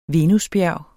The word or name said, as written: venusbjerg